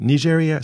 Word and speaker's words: Nigeria: Nigeria, is it – how do you say it in your country? Is it Nigeria or something like that? – Nigeria. – It is correct. – Yes. – Okay. – Very correct.